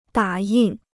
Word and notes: 打印: dǎ yìn

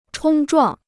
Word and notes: 冲撞: chōng zhuàng

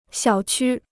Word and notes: 小区: xiǎo qū

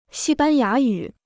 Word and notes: 西班牙语: xī bān yá yǔ

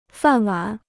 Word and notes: 饭碗: fàn wǎn

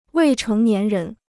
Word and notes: 未成年人: wèi chéng nián rén

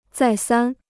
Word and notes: 再三: zài sān

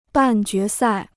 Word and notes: 半决赛: bàn jué sài

